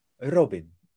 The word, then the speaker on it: Robin: you'd pronounce it like Robin. – Robin. – You Robin. roll your R not too long, like just like touch the R and then okay.